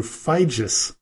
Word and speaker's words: Figes: I was mistaken when I called him Professor Orlando Figes or Figes, but it is pronounced Orlando Figes.